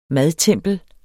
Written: madtempel

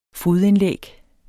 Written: fodindlæg

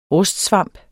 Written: rustsvamp

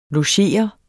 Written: logerende

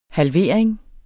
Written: halvering

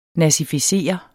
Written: nazificere

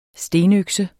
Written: stenøkse

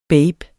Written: baben

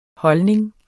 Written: holdning

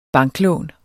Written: banklån

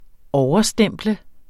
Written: overstemple

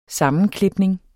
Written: sammenklipning

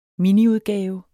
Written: miniudgave

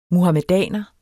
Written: MUHAMEDANER

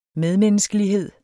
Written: medmenneskelighed

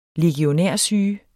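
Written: LEGIONÆRSYGE

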